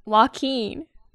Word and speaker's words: Joaquin: Do you say Whacking Phoenix? – Whacking. How do you say it? – Joaquin. – Oh, Joaquin.